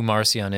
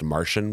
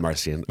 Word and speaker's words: Marcion: Yeah, I just wanted to point out who Marcion is and why he's dead wrong. – And, you know, I've heard his name pronounced Martian before, which I dislike even more because he's like from way out.